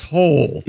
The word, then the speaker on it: Hole: But it's pronounced Hole. – Yeah. – Hole? – Detective A. Hole. Yeah. – It's spelled H-O-L-E.